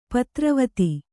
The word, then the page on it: ಪುತ್ರವಧು: Kannada